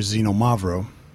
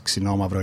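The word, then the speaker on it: Ksinomavro (Ξινόμαυρο): So Ksinomavro means, people usually call here Xenomavro, but the right pronunciation is Ksinomavro.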